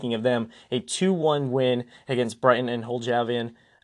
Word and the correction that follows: Albion: I'm pretty sure I butchered the second part of that. It doesn't matter. And Hove Albion, excuse me.